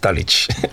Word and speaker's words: Dulwich: You don't really pronounce the W, East Dulwich.